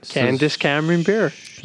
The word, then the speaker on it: Candace Cameron Bure: Candace Cameron Bure. – Yes. – If that's how you pronounce her name. – Yep. I believe so.